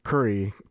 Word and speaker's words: kuri: Curry, as in like the food? – Yeah, almost like the food. So, curry, K-U-R-I, is how it is spelled.